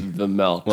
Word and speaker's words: Vejmelka: It's Vemelka. – Well, yeah, I'm either going to call him Melka or Veggie, because Vejmelka... – No, Vemelka. – Vemelka. So the G, yeah. the J is silent. Vemelka.